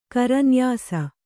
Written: ಕರನ್ಯಾಸ

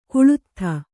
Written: ಕಳಾತೀತ

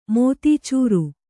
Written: ಮತಾಚಾರ

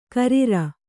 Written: ಖರಾರಿ

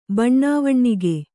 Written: ಬಣ್ಣವಣ್ಣಿಗೆ

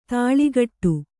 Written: ದಳೆಕಟ್ಟು

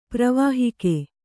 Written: ಪ್ರವಾಹಿಕೆ

